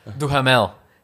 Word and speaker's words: Duhamel: Duhamel.